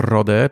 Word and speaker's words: Rodez: E, jeszcze jest takie miasto Rode, pisze się Rodez, to jest chyba w ogóle stolica całego departamentu Aveyron.